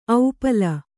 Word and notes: ಔಪಲ: aupala

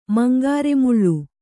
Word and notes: ಮಂಗಾರೆಮುಳ್ಳು: mangāre muḷḷu; Kannada

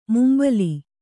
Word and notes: ಮುಂಬಲಿ: mumbali

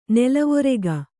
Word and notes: ನಲವರಕೆ: nalavarake

